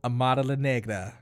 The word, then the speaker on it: Amara La Negra: But my thing with Amira La Negra, or how you say it? – Amara La Negra.